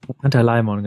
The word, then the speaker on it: Pantalaimon: Äh, Pantalamion. Pantalaimon? Wie spricht man das aus? Pantalaimon? Pantalaimon, genau.